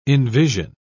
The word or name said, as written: envision